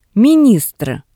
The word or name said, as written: ministr